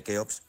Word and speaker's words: Keops: Y eso lo aprovecha muy bien su hijo Keops, que por cierto, para Culturilla General, se escribe Keops, pero se pronuncia Jufu, y casi nadie llama Jufu, digo, porque Herodoto nos lo tradujo del griego y bueno, pues ahí se ha quedado, ¿no?